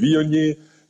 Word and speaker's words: Viognier: nee, Viognier,